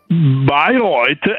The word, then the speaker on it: Bayreuth: Bayreuth, ecco, hanno infilato dei geni anomali, cioè estranei, all'interno di ragnette affinché nascessero ragnetti con una tela modificata.